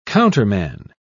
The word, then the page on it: counterman: British English